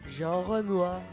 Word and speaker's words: Renoir: Jean Renoir.